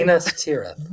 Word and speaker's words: Minas Tirith: Minas Tirith.